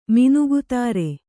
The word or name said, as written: ಮಿನುಗುತಾರೆ